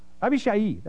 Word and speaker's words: Abishai: And so when Abishai, I'm saying his name wrong, I can't remember how to say it. Abishai, that's it.